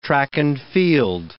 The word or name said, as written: track-and-field